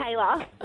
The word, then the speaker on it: Taylor: How do we say it? – Taylor? Was it? No. – Taylor. – Oh, okay, there's just an L in there for good measure.